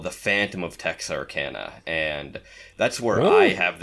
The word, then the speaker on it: Texarkana: Well, I actually have a friend from Texarkana, I totally slaughtered the pronunciation of the town, and I just called it Texarkerner.